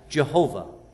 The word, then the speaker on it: Jehovah: And that word we pronounce as Jehovah.